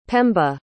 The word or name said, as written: pemba